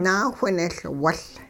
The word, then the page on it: naawhinehLwuL: hupa